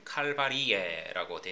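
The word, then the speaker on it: calvariae: 지금 우리는 영어식 철자를 쓰니까요. 캘버리라고 하고 RY로 끝이 나지요. 라틴어는 Y 대신에 IAE로 끝납니다. 그래서 라틴식으로 발음하면 칼바리에라고 되어집니다.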